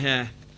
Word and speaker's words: heir: I have trouble saying that word, heir. I think it's because I'm from Yorkshire. I want to say heir. Heir. Just heir, not heir. I'm trying to pronounce the H.